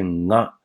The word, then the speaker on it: が: 这是 ga 呢，这个 ga 啊最为基础的一个用法。我们说这个 ga 啊本身它的发音呢有两个，一个是 ga，一个是 nga。 nga gi gu ge go，ga gi gu ge go。